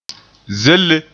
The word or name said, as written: zelle